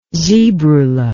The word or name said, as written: zebrula